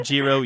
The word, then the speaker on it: gyro: Gyro or Giro, you're okay.